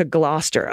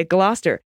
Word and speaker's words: Gloucester: Here's a mnemonic nursery rhyme used to teach the generations of English school children how to pronounce Gloucester. Dr. Foster went to Gloucester. Okay, Gloucester.